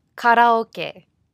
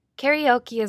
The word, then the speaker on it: karaoke: Alright, so now on to one of my favorite parts of spending time in Japan, karaoke, or the Japanese pronunciation, karaoke. And yes, it really is an important topic to cover for building business relationships in Japan, so be sure to stick around. Karaoke